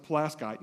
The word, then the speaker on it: Pulaski: In Pulaski County, Indiana, and yes, we pronounce it Pulaski, not Pulaski, Pulaski, I don't know why, we just do.